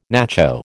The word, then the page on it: nacho: American English